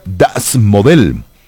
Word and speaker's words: Das Modell: se titula Das Modell. Eso es más o menos la pronunciación en alemán.